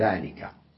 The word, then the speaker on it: ذلك: فلو كانت بقيت وحدها ذا الألف تلفظ وتكتب، لكن إن دخلت عليها لام البعد نسميها عندئذ تلفظ الألف ولا تكتب، نكتبها ذلك وتلفظ ذلك.